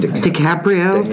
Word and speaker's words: DiCaprio: DiCaprio? – DiCaprio.